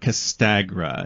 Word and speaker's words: Castagra: But tell me a little bit more about Castagra.